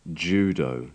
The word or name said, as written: judo